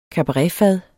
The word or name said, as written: kabaretfad